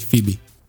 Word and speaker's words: Fibi: Fibi, kiejtés szerint. Mind a jó barátokba Fibi, csak itt magyarul, hogy Fibi. – F-I-B-I.